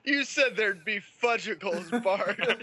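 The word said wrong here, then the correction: Fudgsicle: First of all, it's pronounced fudge-sickle.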